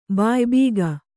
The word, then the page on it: ಬಾಯಿಬೀಗ: bāyi bīga